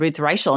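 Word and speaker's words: Reichl: and that's when they said Ruth Reichel. And the first time I was like, I had no idea that's how you pronounce it, because you don't ever hear it, you know? – Yep.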